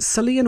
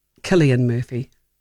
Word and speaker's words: Cillian: Is it Cillian or Killian Murphy? Killian Murphy. He's got the most fabulous face. Doesn't know anything about social media at all. Didn't know anything about the fabulous Killian Murphy memes that were doing the rounds, where he just looks so bored.